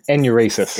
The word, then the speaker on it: enuresis: Uresis? Is that how you say it? – Enuresis. – Enuresis.